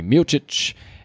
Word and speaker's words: Miocic: Yeah, break down the entire from the first preliminary, early preliminary, all the way to the main event of Stipe Miocic. Got to say it correctly, Miocic and Francis Ngannou.